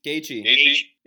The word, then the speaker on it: Gaethje: You got Tony Ferguson versus Justin, I don't know how to say his last name, what, Gaethje? – Gaethje.